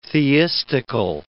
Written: theistical

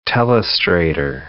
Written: Telestrator

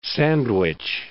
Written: Sandwich Islands

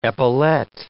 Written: epaulette

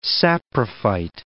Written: saprophyte

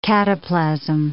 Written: cataplasm

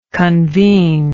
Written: convenor

